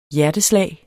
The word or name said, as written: hjerteslag